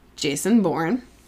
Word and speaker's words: Jason Bourne: So it's actually most famous sufferer is a fictional character, Jason Bourne.